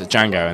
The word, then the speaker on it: Django: The you know, the scene with Jamie Foxx, and he's like, he says his name is Django, and the guy says, the D is silent.